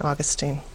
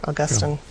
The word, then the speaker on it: Augustine: And the most we ever would think about was St. Augustine or Augustine, depending which. Yeah. I still use the Protestant pronunciation, I realize.